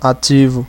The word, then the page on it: live: American English